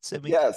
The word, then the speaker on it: Sevink: Like it's like the Sevink. Is that how you say it? Sevink? – Yes. Like, it's just the word knives backwards, actually, is where the title came from for that.